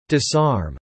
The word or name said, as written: disarm